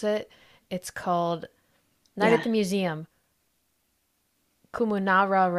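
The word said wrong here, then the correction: Kahmunrah: Rises Again. Kamun Ra Rises Again. There we go.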